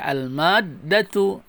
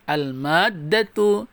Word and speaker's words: al-maddatu: Ini uh, masuk kepada masalah susah dan mudah nih ya al-maddah ada dalnya tasdid ya al-maddatu nah gitu ya bacanya al-maddatu